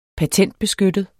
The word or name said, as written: PATENTBESKYTTET